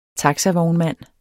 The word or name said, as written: taxavognmand